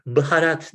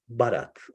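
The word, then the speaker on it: Bharat: Baharat değil, Barat. O BH harfi Hintlilerin kullandığı Latin alfabesinde kuvvetli bir B şeklinde telaffuz ediliyor. Hintçe'de iki tane ayrı B var. Bir tane önden, bir tane arkadan söylenen, bir tane dudak kapalı, bir tane açık söylenen.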